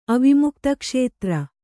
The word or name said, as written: ಅವಿಮುಕ್ತಕ್ಷೇತ್ರ